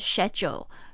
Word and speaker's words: schedule: 这边的 schedule，呃，它的发音是 schedule。这个 schedule 其实是英式发音哦，在美式发音比较有可能会听到 schedule，但是英式的口音都是讲 schedule，schedule，好像水饺，schedule。水饺。